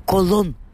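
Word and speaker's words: Colón: И с сегодняшнего дня вещание на русском радио «Стерео из Испании» будет называть этого человека Христофор Колон. Вот такое наше ноу-хау. Вот такое наше с Алексеем Ершовым ноу-хау. Имя первого открывателя Америки мы будем произносить в испаноязычном варианте, так как его зовут в Испании, так как его зовут во всей южной части американского континента, Латиноамерики и Иберо-Америки.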